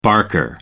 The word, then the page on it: barker: American English